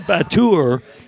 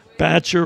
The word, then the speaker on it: batture: And if you say Batur, you're either like putting on airs or you just don't know any better. – Uh-huh, because, okay. right, in New Orleans, Batcher rhymes with catcher.